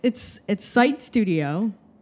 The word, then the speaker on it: Psyched Studio: It's Psyched Studio, and it would the genesis of the idea for the name was this combination of sight SITE like project site and eyesight SIGHT,